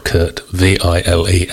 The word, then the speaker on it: Kurt Vile: When I first heard of Kurt Vile, I presumed it was a play on words from the German composer, well, a beggar's opera for one thing, Kurt Vile, spelt with a W and E-I-L-L. And I thought it was just a play on words because this guy is spelt Kurt, V-I-L-E, as in vile. But I've since learned that his name is actually Kurt Vile, and he was christened Kurt Vile.